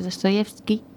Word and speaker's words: Dostoyevsky: era una versión del Crimen y Castigo de Dostoyevsky. Lo he pronunciado bien, seguro. – Sí, sí, sí.